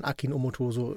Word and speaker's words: Akin Omotoso: Ich habe letzte Woche seinen Namen falsch ausgesprochen. In meinem jugendlichen Leichtsinn und der Euphorie, die ich da für seinen Film hatte, ist es mir rausgerutscht. Deswegen Grüße von hier an Akin Omotoso.